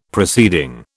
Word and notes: proceeding: British English

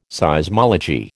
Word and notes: Seismology: American English

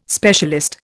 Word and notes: specialist: Indian

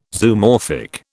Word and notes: Zoomorphic: British English

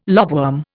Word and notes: lobworm: Indian

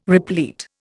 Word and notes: replete: Indian

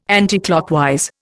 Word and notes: anticlockwise: Indian

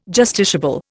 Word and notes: justiciable: Indian

